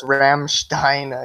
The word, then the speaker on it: Rammstein: I listen to a lot of Rammstein. You pick up words that you don't understand but you can pronounce. – Please never say Rammstein ever again. – No, it's Rammstein. I messed it up.